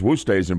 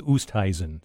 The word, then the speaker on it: Oosthuizen: I think it's Woosthuizen, but it looks like an eye chart. I mean, it's very hard to look at that name and pronounce it. – Well, the little pronunciation I was given here says Oosthuizen.